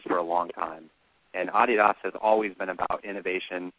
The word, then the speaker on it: Adidas: Big part of, in the last 60 years, of Adidas. – It is, and I'm actually very proud of you because when you introduced me, you said from Adidas Golf, and that's actually the correct way to say it.